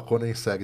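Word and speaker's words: Koenigsegg: Tem o, o sueco, é, Coniseg. Coniseg. É, essa é uma lacuna no meu currículo, nunca andei. – Nunca andou? – é, esse, eu, eu, eu, eu, agora aprendi que fala Coniseg.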